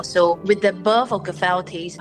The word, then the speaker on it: Gifel Tea: Is that how you pronounce the name? – Yes, Gifel Tea, that's right.